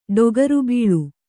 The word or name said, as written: ಡೊಗರುಬೀಳು